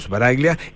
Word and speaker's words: Sbaraglia: Si vos me decís a la italiana, sería Sbaraglia, pero nadie Sbaraglia. Dice así. O sea, Sbaraglia, en italiano se dice Sbaraglia, pero en Argentina es Sbaraglia, y vos lo dijiste mejor que está bien, está perfecto.